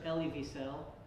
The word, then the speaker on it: Elie Wiesel: Have you ever heard of Elie Wiesel? – Yes.